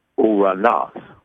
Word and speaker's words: Ouranos: Uranos. – Uranos. – Mm-hmm. Urano. It spells like this. O-U-R-A-N-O-S. Uranos. Now, remember now, this is Greek language, not English language. Uranos. So just like the vowel sounds in Spanish is not A-E-I-O-U.